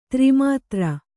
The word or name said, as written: ಧರ್ಮದೂರ